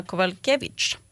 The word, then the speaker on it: Kowalkiewicz: Joanna Jędrzejczyk versus Karolina Kowalkiewicz. Som ska mötas. – Bra uttal också på efternamnen.